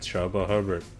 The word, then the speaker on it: Chuba Hubbard: Was it Chuba Hubbard? – Say it again? – Chuba Hubbard, however you say his name. – Yep, Chuba Hubbard.